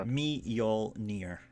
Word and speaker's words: Mjolnir: How is it pronounced, sir? – Mjolnir. – Mjolnir.